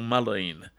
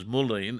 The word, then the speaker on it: mullein: Now, listeners will say uh, mullein or mulline. Some refer to it as mulline, but I pronounce it as mullein, M U L L E I N.